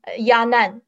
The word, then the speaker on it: Yanan: But through one pronunciation, for example, my name, Nan, Yanan, I take Nan as example. Nan, its initial is N. So in Chinese, it pronounced N. A-N is An. So its Chinese pronunciation is Nan.